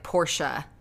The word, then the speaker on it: Porsche: And one time when I said it, he was like, Porsche. And I was like, oh, is that how you say it? He's like, yes. I said, okay. So I have changed it to Porsche.